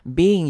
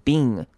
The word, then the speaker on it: being: Being 也是一样，是 be。 in 两个声音所组成的，所以不要把 being 这样念，就是念成 being，这样就不对了，要念成 be 再加一个 in，所以记得 ing 前面要有一个 y 的声音，y 的声音出现 being， you're not being his true friend。